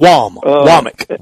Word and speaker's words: Womack: But, I mean, his thought process, I'm sure, and I think it's Womack. Is how it's pronounced. – It's Womack. – W-O-M-A-C-K.